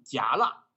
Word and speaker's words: 戛纳: 刚才我们的主播在讨论这个字应该怎么读的话，我我现在告诉你们，这个字应该读戛纳。